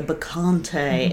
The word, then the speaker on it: Bacchante: It's spelled B-A-C-C-H-A-N-T-E, by the way. – Yeah. – By the way. Hopefully in case there's going to be a spelling test later, so... Yes. Remember that. – Please do. And hopefully we're saying it right. I would think so. – Yeah, I think your pronunciation is spot on.